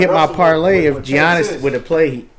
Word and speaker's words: Giannis: G is silent. Giannis. It's Giannis.